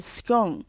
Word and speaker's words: scone: 听到的都是 o 的音，也就是 scone，但是有另外一派发的是 r 的音，就像 gone、 one 的念法。